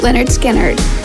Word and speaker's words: Lynyrd Skynyrd: MCA Records released Lynyrd Skynyrd's debut album, pronounced Lynyrd Skynyrd, which went to number 27 on the Billboard Top LPs chart.